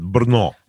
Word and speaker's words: Brno: And that's how you pronounce it, Brno. Brno.